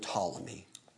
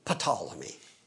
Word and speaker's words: Ptolemy: And then south of him, Egypt, especially northern Egypt, northern Africa, that area falls to Ptolemy, a general named Ptolemy, with a silent P on the beginning, Ptolemy.